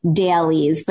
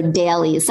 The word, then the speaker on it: Daleez: It's called dailies, but it's spelled D-A-L-E-E-Z. So dailies. Sometimes I Frenchify it and I say dailies, but why not? – Dailies.